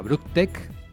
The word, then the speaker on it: Brooktech: Bueno, David además dirige su propia agencia de desarrollo de software que se llama Brooktech. ¿Lo he dicho bien? – Exacto, muy bien.